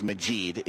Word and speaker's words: Majid: My actual name is Majid. It's 5 letters, 2 syllables.